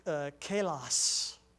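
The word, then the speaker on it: kalos (καλός): The word good in the Greek is a word that's said it's pronounced kalos.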